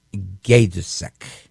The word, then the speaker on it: Gajdusek: This is a very grotesque man, a very evil man, and his name is Gajdusek. I think I'm saying that right, Gajdusek. This guy, Daniel Carlton Gajdusek, and that's G-A, soft J, D-U-S-E-K. It's a Slavic name.